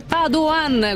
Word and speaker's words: Padoan: Poi leggo un messaggio di un ascoltatore, dice si pronuncia Padoan, guardate che io lo dico alla veneta, però pare che il ministro dell'economia Padoan abbia detto voglio essere chiamato Padoan, non so, ognuno ha le sue preferenze evidentemente.